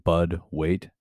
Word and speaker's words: Bud Waite: or you can follow me at Bud Waite.